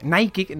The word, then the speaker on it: Nike: Que esto es un spoiler de que la próxima temporada de Apple, de la serie, vamos a tener a Nike como marca, Nike, ¿no? Como sería la pronunciación correcta, dentro del AFC Richmond.